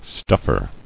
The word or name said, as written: Stuffer